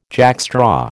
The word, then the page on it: jackstraw: American English